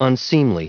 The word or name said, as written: unseemly